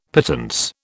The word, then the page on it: pittance: British English